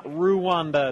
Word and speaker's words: Rwanda: Rwanda is spelled R-W-A-N-D-A. It's not Rwanda, it's Rwanda.